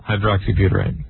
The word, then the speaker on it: hydroxybutyrate: How do you say it, though? I screwed that up. – Gamma. – And it's a drug. – Gamma? – Hydroxybutyrate. – There you go.